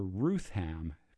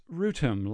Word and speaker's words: Rootham: When the composer was teaching at Cambridge, at the start of a term, one of the students addressed the composer as Professor Rutham, because it's spelled R-O-O-T-H-A-M. And the composer stopped and he said, "It's Rutem, like Beethoven."